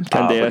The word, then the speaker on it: Candeias: But uh, in terms of expected assists, and, and, I hope I don't say his name wrong, uh, but for Rangers, the, the leader in the league is Diaz. Is that uh, did I did I get that? Uh, Candeias,